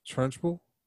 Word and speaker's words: Trunchbull: Is that how you say her name? Trunchbull? Trunchbull? – Yeah, Trunchbull.